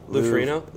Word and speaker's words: Ferrigno: Lou Ferrigno.